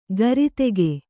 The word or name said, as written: ಗರಿತೆಗೆ